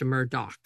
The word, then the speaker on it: Murdaugh: An article I read specifically said pronounced Murdaugh. – Yes. – And had D-O-C-K.